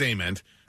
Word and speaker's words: Ament: Jeff Ament celebrates his birthday, or Ament, however you prefer to announce that or pronounce it. – He says Ament. Eddie says Ament.